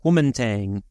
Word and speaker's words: Kuomintang: Kuomintang? – Kuomintang.